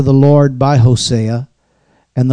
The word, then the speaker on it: Hosea: But the first passage says, and this is from the book of, depending on who you are, how you pronounce it, there's actually several correct ways of pronouncing it. Hosea, any one of them is correct. So I'll just say Hosea.